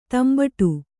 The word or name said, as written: ದಂಬಡಿ